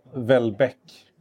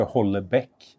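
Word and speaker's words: Houellebecq: Jag uttalar inte honom felaktigt, det vill säga jag säger inte Hollebeck som ingen någonsin har sagt. Jag uttalar inte det efter bokstäverna. Nej. Men däremot så säger jag inte heller mer som det låter på franska, Welbeck.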